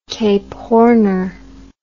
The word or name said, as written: Cape Horner